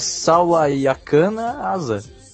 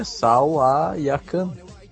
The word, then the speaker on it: Sawayakana: Não, é Sawayakana Asa. – Sawayakana, né? – É Sawayakana. – Sawayakana. – É que nem falar cana de açúcar, pô.